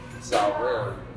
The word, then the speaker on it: tselem: It's spelled into English T-S-E-L-E-M. Salim.